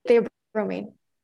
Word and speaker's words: theobromine: Theobromine.